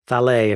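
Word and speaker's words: Thales: And everybody, we got, I think, multiple follow-ups from listeners correcting our pronunciation, and I believe it is Thalée if you're French.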